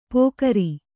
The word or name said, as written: ಪೊಗರಿ